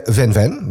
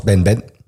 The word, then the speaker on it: Van Van: Ja, in deze double play hoor je Van Van, maar dat moet je eigenlijk uitspreken als Ben Ben. Ja, de V is de B, hè? Ja, dat is het niet, maar zo spreek je het uit.